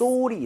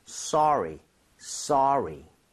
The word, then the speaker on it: sorry: 대신에 발음할 때 소리는 좀 심하죠. 소리는 아니니까 써에 가깝게 입모양 살짝만 벌려주고 발음했으면 좋겠어요. 그래서 Yes. – sorry 이렇게 하지 마시고요. sorry, sorry.